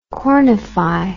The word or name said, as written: cornify